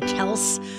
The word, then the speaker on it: Kelce: Okay, so Travis Kelce, you know we're pronouncing his name wrong, right? It's really Kelce.